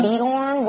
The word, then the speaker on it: Beorn: Beorn, whatever、mm-hmm.